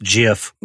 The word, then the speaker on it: GIF: Yeah, and I think it's pronounced GIF, like peanut butter.